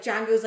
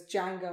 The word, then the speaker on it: Django: And he goes, Django. And he goes, how do you spell that? And he goes, D-J-A. Oh my God. – Wow. – D-J-A-N-G-O. – Yes. And then he says, the D is silent.